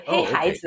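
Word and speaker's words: Heihaize (黑孩子): Well, I was struck by your description of, and I know I'm going to say this wrong, Heihaize. Is that correct? – Very good. Heihaize.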